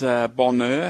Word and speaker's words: Bonheur: Been knocked silent by the silent H in Bonheur.